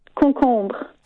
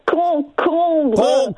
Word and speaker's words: Concombre: Catherine has typed on my screen, it's one of the hardest words for a Brit to pronounce. I think I'm saying it perfectly. Concombre. Concombre. Is that right? – No. Concombre. – Concombre. Concombre. – Why are you rolling the R's? – Because I'm French.